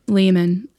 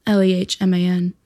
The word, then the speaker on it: Lehman: It's Kaci, and then underscore Lehman, Lehman. Say it right.